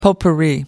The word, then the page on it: potpourri: American English